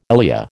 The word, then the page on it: ILEA: American English